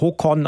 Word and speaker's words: Håkon: Ich lerne nebenbei Norwegisch und weiß mittlerweile, dass der jetzige König, der Håkon geschrieben wird, Håkon ausgesprochen wird, der Sohn von Harald.